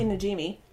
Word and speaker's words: Najimi: The main actors are Sarah Jessica Parker as Sarah Sanderson, Betty Midler as Winifred Sanderson, and Kathy Nimji? I don't know how to pronounce that. – Let me see. – Najimi? – Oh, okay. Kathy Najimi.